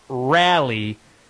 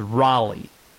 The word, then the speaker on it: Raleigh: It's not Rally, it's Raleigh. Raleigh is the proper pronunciation.